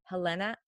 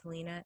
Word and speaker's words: Helena: which was one of them was Helena. I don't want to say Helena because my sister's name is Helena, and it's not pronounced that way. I want to say Helena Stokely.